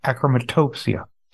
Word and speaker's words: achromatopsia: First one being congenital nystagmus, and another one being achromatop- achromatopsia. Is that how I pronounce it? – I believe so, yeah. – Okay, achromatopsia. How did both eye diseases affect your vision? – Well, it's interesting enough.